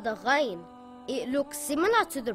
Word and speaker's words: Ghain: Ghain is a heavy letter and should be always pronounced heavy. It is as if you are gargling some water from the throat. Ghain.